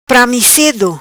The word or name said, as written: Pramicedo